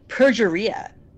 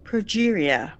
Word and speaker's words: progeria: She mispronounces it. She says progeria. Like, you would think that Tony Scott would stop her and say, it's pronounced progeria.